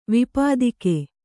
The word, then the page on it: ವಿಪತಾಕ: vipatāka; Kannada